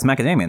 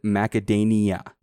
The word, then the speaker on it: macadamia: Yeah, it's macadamia nut. – The first time I said macadania. Macadamia.